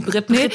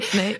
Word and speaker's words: Britney: Und vor allem geht es eben darum, dass ein Zusammenhang hergestellt wird zwischen dem Absturz von Britney Spears, jetzt habe ich jetzt sehr deutsch ausgesprochen, die Britney, Britney.